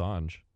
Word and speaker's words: Assange: Okay, so it's just Assange. – Is it Assange? I've been saying Assange.